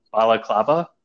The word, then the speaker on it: balaclava: And that is, I'm sure I'm going to mispronounce it, it's not baklava, because that's the dessert, but I think it's called balaclava.